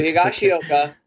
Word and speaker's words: Higashioka: So Higashioka.